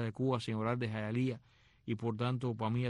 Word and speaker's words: Hialeah: palabra indígena que se escribe Hialeah, Jayalía, que la vi por primera vez en aquel sobrecito de espejuelito. ¿Qué cosa es Hialeah? Oh, Hialeah es Jayalía.